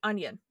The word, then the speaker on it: Union: And I guess in North Carolina there's a county called, and it's, you know, UNION, Union, but it's Union. They pronounce it onion. The entire, like, it's Onion County.